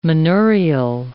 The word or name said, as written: manurial